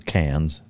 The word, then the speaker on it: Cairns: Cairns or Cairns, the way they pronounce it.